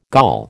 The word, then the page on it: Gaul: American English